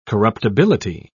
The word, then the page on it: corruptibility: British English